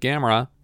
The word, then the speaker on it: Gamera: Gamera, Gamera, Gamera, Gamera. – No, it's more like Camera versus Gamera. So you're putting the emphasis on the second syllable instead of the first syllable.